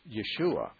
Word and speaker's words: Yeshua: Jesus' name in his own native tongue was Yeshua. That was the Aramaic and the Hebrew pronunciation of his name.